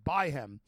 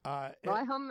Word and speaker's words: Byhem: Byhem is fine, but most people don't even bother to pronounce the H, so it's just Byhem. – Byhem, right. – The H recedes so far into the back of the throat that you don't hear it.